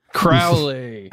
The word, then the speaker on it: Crowley: Rhymes with Owley. – Oh, Alistair, our boy Allie. – Crowley.